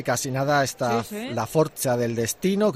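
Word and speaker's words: forza: Bueno, yo creo que está más cerca de la forcha del destino que de la fuerza del destino, pero en cualquier caso, la forcha del destino, 4 funciones, 25, 28, 31 de octubre y 3 de noviembre, como decíamos, en Euskalduna, Bilbao.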